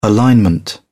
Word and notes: alignment: British English

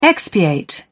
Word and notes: expiate: British English

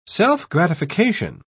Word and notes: self-gratification: British English

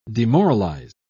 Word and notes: demoralised: British English

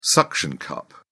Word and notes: suction cup: British English

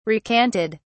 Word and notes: recanted: British English

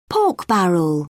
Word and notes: pork barrel: British English

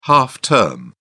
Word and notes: half-term: British English

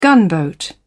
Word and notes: gunboat: British English

